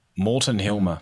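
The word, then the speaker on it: Morten Hilmer: So people who are listening, how do you say his name exactly? – Morton Hilmer.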